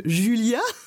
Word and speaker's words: Julia: Donc Julia, on prononce bien Julia, c'est ça On prononce bien Julia.